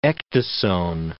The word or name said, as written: ecdysone